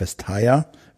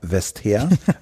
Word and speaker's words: Vestager: Also Sven Giegold besteht auf Vestager wir bestehen auf Westher, weil wir da sehr fest von überzeugt sind, dass das die richtige Aussprache ist.